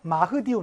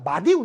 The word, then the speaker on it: 마흐디: 요 수단에서 영국에 저항했던 마흐디 운동. 요 흐를 빼서 발음하기도 합니다. 마디 운동이라고도 해요.